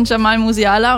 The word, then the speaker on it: Jamal Musiala: In ihrer Story bedankt sie sich beim FC Bayern-Spieler wie Luis Diaz, Harry Kane, Jamal Musiala und Alfonso Davis. Habe ich alles richtig ausgesprochen, oder? – Ja, korrekt.